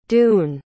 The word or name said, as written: doon